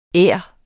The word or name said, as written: ær